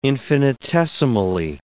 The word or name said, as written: infinitesimally